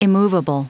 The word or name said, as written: immovable